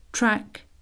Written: track

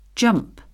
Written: jump

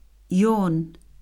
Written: yawn

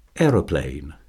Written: aeroplane

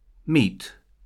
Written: meat

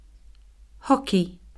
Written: hockey

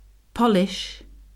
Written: polish

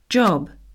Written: job

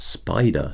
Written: spider

